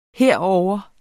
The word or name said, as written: herovre